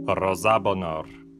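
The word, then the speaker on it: Роза Бонёр: Прежде чем мы начнем, буквально одно слово об ударении. «Роза» — это сокращение от «Розалин». Во всех французских интервью, которые я посмотрел, именно такое ударение употребимо, и я посему стану придерживаться оригинала.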